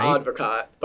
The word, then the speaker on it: Advocaat: It's pronounced Advocaat, right? – Advocaat. But I like to tell people Advocaat, Advocate, Advocate. I just tell people everything, but it's Advocaat.